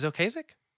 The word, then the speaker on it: Ocasek: Is Ocasek? Am I pronouncing that right? Rest in peace. – That's the way I've always said it in my head.